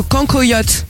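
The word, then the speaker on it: cancoillotte: Le cancoyote. – Oui. – Ça se prononce comme ça? – Oui, ça se prononce comme ça.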